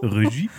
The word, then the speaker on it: Regie: Das ist die französische Aussprache. Regie.